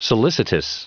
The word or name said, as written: solicitous